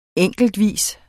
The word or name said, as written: enkeltvis